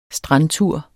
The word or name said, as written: strandtur